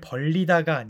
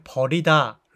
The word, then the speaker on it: 벌이다: 일을 벌리다가 아니라 일을 버리다입니다.